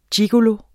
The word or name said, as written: gigolo